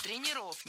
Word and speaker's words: тренеров: Тренеров, неверно. Перед нами, опять же, слово с неподвижным ударением. И двигать это ударение мы не должны. Всегда ударяем «е» в первом слоге. Тренеры, тренером, тренеров.